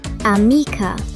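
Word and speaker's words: amie: Und Freundin heißt ami. Das spricht man genauso. Man schreibt es nur etwas anders.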